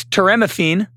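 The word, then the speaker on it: toremifene: Toramifini. Oh, my God. Is someone else want to try this? I can't. – I think it's teramifine.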